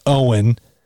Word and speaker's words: Eoin: His first name is spelled E O I N, it's pronounced Owen.